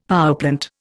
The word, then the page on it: powerplant: Indian